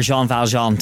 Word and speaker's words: Jean Valjean: You're Jean Valjean.